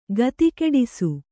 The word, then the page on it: ಗತಿಗಿಡಿಸು: gatigiḍisu